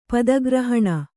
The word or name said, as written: ಪದಗ್ರಹಣ